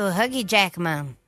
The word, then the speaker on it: Hugh Jackman: É aniversário também do Wolverine, o ator Hugh Jackman, completando 55 anos. Falei certo o nome dele? – Isso, Hugh Jackman.